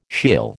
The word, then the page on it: Sheol: American English